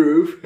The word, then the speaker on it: growth: De growth. – Ja, waarschijnlijk spreek je het echt helemaal verkeerd uit. Nee, je, nee, je zei het echt denk ik perfect.